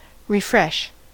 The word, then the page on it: refresh: American English